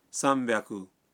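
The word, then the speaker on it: سام بیاکو (さんびゃく): جی ہاں, بنیادی طور پر تو ایسا ہی ہے, لیکن بعض اعداد کا تلفظ کچھ مختلف ہے. مثلا 300 ہے سام بیاکو,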